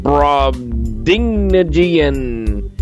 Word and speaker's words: Brobdingnagian: Brobdingnagian.